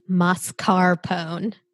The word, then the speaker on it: mascarpone: I think we all learned that it's mascarpone. And that's exactly how you pronounce it in Italy.